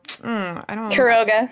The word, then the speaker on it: Quiroga: Quiroga.